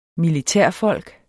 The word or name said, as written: militærfolk